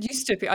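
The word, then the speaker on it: ustopia: And to capture this intermingling of these two perspectives, she has coined the term astopia. You pronounce it differently, so I'm sure. – Yeah, so I said eustopia. I assumed it was like utopia, dystopia. – I think you're absolutely right.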